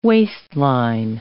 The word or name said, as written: waistline